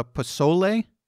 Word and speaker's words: pozole: and that's pozole.